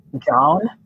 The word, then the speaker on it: jawn: In Philadelphia, and my Philadelphia friends are going to laugh, the word that means the most is John, and it is spelled J-A-W-N,